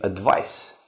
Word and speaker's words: advice: Advise, it means to give advice. And this is a device. Noun, it is a noun, and this is a verb. So we write them and pronounce them differently.